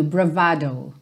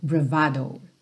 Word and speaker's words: bravado: Então, como ela vem do italiano, a pronúncia fica ali no meio do caminho, bravado, bravado.